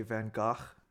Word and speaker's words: Van Gogh: Like, even if we say, like, you know, artists like Van Gogh, or, you know, as the Dutch say, Van Gogh is apparently the correct pronunciation of that name.